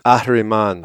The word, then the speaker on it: Ahriman: I'm going to have you pronounce it the Farsi style, which is Ahriman. Ahriman. There it is.